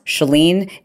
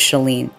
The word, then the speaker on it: Chalene: My name is pronounced Chalene. It rhymes with Celine.